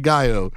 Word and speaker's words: Gallo: It's technically Pico de Gallo, but the whole joke, my name's spelled like Gallo.